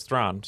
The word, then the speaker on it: Thrawn: Right, because so basically the name is spelled sort of what you would be familiar with as like Charlize Theron, or how we say it here, probably incorrectly, but correct pronunciation is Thrawn.